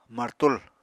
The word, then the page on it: martul: apatani